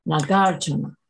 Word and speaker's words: Nagarjuna: And then Arjuna, so we have two A's, and that means it will be a long A. Mm-hmm. So a long A is pronounced like an accentuation. Nagarjuna.